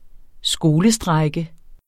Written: skolestrejke

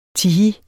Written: tihi

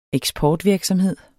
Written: eksportvirksomheder

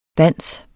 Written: DANSES

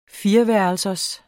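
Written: fireværelsers

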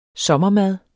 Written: sommermad

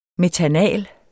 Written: metanal

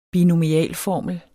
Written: binomialformel

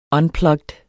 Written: unplugged

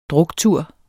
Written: druktur

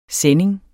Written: SENDINGERNES

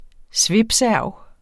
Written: svipserv

